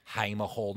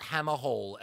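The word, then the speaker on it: Hamer Hall: Haima Hall, not Hammer Hall, as someone said to me the other day. I was like, how did you not know it was Haima? – So it's Haima.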